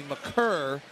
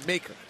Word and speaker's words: Maker: Howard Beck wrote a big feature on Thon, and they came out with it that his last name is actually McCurr, but everybody's calling him Maker. Pronunciation guide still says Maker. Kind of believe Howard that it's McCurr.